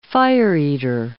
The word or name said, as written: fire-eater